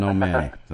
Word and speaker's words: O'Mahony: Simon O'Manny, if you were to Google his name, you would never find it if you were spelling it phonetically, because it's actually spelled as if it were Simon O'Mahony, but it's pronounced Simon O'Manny.